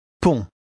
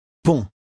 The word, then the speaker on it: pont: Pont. – Notice the final T of word 3 is not pronounced at all. It's just as though it weren't there. Read 3 again. – Pont.